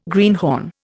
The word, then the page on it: greenhorn: Indian